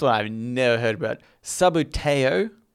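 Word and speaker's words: Subbuteo: Yeah, there's a game called Sabuteo.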